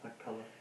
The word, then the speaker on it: Machpelah: that he may give me the cave of Machpelah. Did I say that right? – Machpelah.